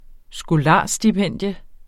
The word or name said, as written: skolarstipendium